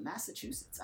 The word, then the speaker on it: Massachusetts: I have a woman from Massachusetts. I've actually said that right this time. I remember I couldn't say that correctly out loud for anything.